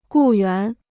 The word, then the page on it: 雇员: gù yuán